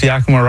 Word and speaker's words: Yakima: And well done. You pronounced Yakima right. You know, everyone screws that up.